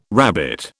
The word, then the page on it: rabbit: British English